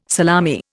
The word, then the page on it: salami: Indian